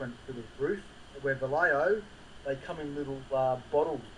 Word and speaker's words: Vallejo: And I'm seeing that, are you still painting with Vallejo? Is that how to pronounce it? – Yeah, Vallejo paints, yeah.